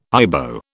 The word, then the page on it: Ibo: British English